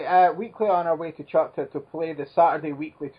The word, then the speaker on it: Choctaw: To Choctaw. – Choctaw. Oh, Choctaw.